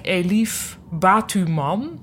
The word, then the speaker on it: Elif Batuman: ik weet niet hoe ik haar naam moet uitspreken, maar ik denk Elif Batuman. Dus het is zeg maar Batman met een U ertussen.